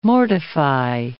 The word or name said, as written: mortify